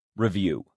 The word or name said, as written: review